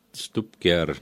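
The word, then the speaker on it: Stubkjær: Tegemist on Taani nimega ja ma arvan, see Stupkjaar, nagu sa ütlesid, kõlas päris kenasti.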